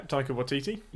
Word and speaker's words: Taika Waititi: Yeah, Taika Waititi.